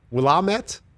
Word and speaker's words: Willamette: I had no idea that it was the Willamette.